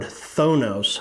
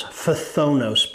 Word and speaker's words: phthonos: And then we studied the word thonos, phthonos, P-H-T-H-O-N-O-S. It's a mouthful. I probably have not said it correctly, but it is properly the word translated envy.